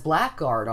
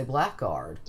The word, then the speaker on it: blackguard: And this is another word that spelling pronunciation has reemerged, and it is listed as blackguard often. So it's not incorrect now to say blackguard.